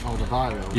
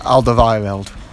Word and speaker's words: Alderweireld: Aldevarel. – Yeah, him. – Oh, yeah. – Give the mic, he knows how to say it. – Alderweireld.